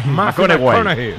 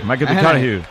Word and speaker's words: McConaughey: Que hay que ir corrigiendo la pronunciación. – McConaughey. McConaughey.